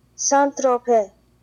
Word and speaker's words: Saint-Tropez: Saint-Tropez.